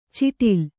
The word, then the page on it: ಛಿಟಿಲ್: chiṭil